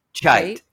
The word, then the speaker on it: Cheit: Chite.